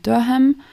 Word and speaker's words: Durham: Ich dachte erst, es heißt Durham, so wie der Schinkenham. Aber sie sprechen das eher kurz aus und betonen eher die erste Silbe und dann ist es sowas wie Durham.